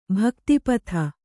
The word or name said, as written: ಭಕ್ತಿಪಥ